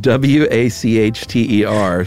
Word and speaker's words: Wachter: W A C H T E R, pronounced Wachter.